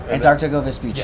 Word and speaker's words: Antarctigo Vespucci: Antarctigo Vespucci. – Yes, thank you for saying it right for me.